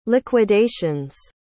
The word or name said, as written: liquidation's